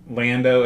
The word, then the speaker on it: Lando: You have weird ways of pronouncing these names. – Londo, Leia. – How do you pronounce it? – Lando and Leia. Like they're supposed to be.